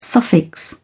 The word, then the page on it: suffix: British English